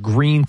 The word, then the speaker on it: Gruene: When my wife's from New Braunfels, Texas, and there's a really cool little town called Green, Texas. It's spelled, it looks like it's spelled Gruene, G-R-U-E-N-E.